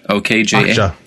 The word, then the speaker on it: Okja: Is it Okja? OKJA. Okja. This